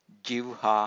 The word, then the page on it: jivha: Pali